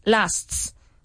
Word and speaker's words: lasts: Lasts. – There you have it. Much better. Okay, so but you have to make sure to pronounce the first S.